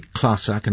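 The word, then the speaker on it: clàrsach: Its proper name is the Classach, and I make sure I pronounce that, I think, correctly, yes. – But tell me if I'm not.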